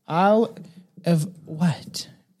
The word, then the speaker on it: Isle of Wight: No, Isle of Wight, right? Isle of Wight. It's spelled W-I-G-H-T.